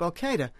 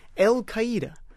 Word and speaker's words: Al-Qaeda: Like, I heard him recently talking about the Middle East terrorist group Al-Qaeda, which most people say Al-Qaeda or Al-Qaeda. He now pronounced it, I'm not exaggerating, El-Qaeda. It's gone from a Middle East terrorist group to a Mexican restaurant.